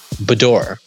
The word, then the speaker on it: boudoir: It's called what? – Boudoir. – Boudoir. – Yeah. – Did I pronounce it correctly? – French. It's a French word. Yeah, that's what it is.